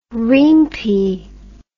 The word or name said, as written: riempie